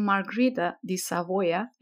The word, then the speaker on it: Margherita di Savoia: This is due to a curse that was launched by Queen Margherita di Savoia. Am I pronouncing that right? – Yes, you are.